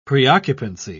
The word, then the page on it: preoccupancy: British English